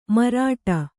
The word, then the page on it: ಮಾರಾಟ: mārāṭa